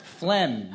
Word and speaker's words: phlegm: Mucus, vomit, phlegm, urine. – Phlegm. – And the silent letter? – G.